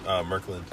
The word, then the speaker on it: Murkland: Murkland.